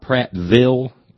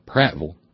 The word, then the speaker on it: Prattville: Enter John Delwyn Jordan, who attended, and he's from a place called Prattville, not Prattville, you got to say it right, Prattville.